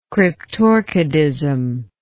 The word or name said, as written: cryptorchidism